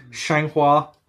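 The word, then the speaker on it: Xianghua: Shanghua.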